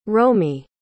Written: romie

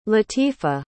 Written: lateefah

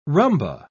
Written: rumba

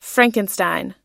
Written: frankenstein

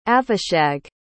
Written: avishag

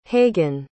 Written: hagen

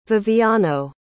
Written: viviano

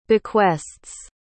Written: bequests

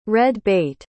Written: red-bait

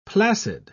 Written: placid

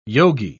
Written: yogi

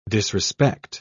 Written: disrespect

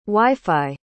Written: wifi

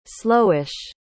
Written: slowish

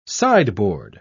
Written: sideboard

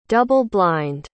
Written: double-blind

